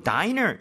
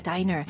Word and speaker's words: diner: 그리고 diner, 이거 dinner가 아니라 diner 맞죠? – 맞아요, diner. 스펠링은 이제 dinner하고 비교하면 n이 하나 빠져 있죠? – 네.